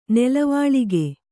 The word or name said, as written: ನೆಲವಾಳಿಗೆ